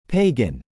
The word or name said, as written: pagan